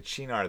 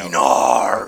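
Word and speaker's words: Cynar: The Chinar though had an interesting bottle.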